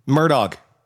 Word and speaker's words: Murdaugh: Murdaugh. Murdaugh. Alec Murdaugh. There we go. Do we got that? Alec Murdoch.